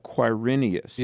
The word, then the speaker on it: Quirinius: It's actually spelled with a Q, like Quirinius.